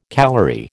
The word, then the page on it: calorie: American English